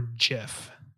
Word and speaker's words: GIF: So, GIF or GIF? – It's a hard G.